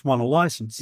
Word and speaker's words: Whannalysents: Well, if you say it phonetically, it's won a license.